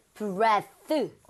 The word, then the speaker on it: breath: Breath. – 어디까지? – 이거까지입니다. – 예, 이가 안 붙고요. – Breath. – 발음도 breathe가 아니라 breath.